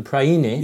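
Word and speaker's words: Priene: I don't know how to pronounce it. The Praene. The Praene. – Yes, yes. – Inscription. – It's a Greek place name. It's Western Turkey. – That's right. – Praene. Yes. And I think it's dated...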